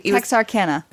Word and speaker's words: Texarkana: Like, there was like Texarkansas, something. – Texarkana. – Thank you. Every time I try to say that, I never know how to say it.